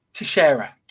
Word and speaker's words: Teixeira: Jack Teixeira. Spanish Portuguese name. – Sure. That's better than anything else I can come up with. So Teixeira.